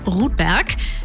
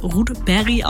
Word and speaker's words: Rudberg: So läuft's bei Omar Rudberg. Beziehungsweise, er kommt aus Skandinavien. Eigentlich spricht man seinen Nachnamen wohl eher Rudberry aus.